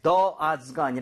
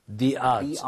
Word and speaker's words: the odds: 대신에 the odds가 아니라 the odds. – The odds. Okay? O 라고 하는 발음 있죠. 모음 사운드이기 때문에 너가 아니라 the.